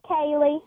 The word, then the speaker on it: Kaylee: And we go to Louisiana and Callie, it looks like. I think I got the pronunciation right. Welcome to the program, Callie. – It's Kaylee.